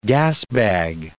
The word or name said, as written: gasbag